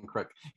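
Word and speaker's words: Kreuk: He also had some really, really great chemistry with Kristen Kirk. – Kristen Crook. Yeah, say that 5 times fast. – Kristen Crook.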